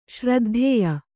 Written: ಶ್ರದ್ಧೇಯ

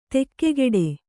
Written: ದಿಗ್ಗೇಡಿ

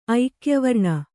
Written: ಐಕ್ಯವರ್ಣ

